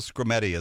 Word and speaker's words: Skrmetti: It's called Scrimeti. It's spelled S-K-R-M-E-T-T-I, named after the Attorney General for the state of Tennessee.